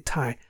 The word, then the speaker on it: Thai: and I guess at some point the Thai gentleman, am I saying Thai? – Yeah, you're saying it right.